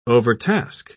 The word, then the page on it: overtask: British English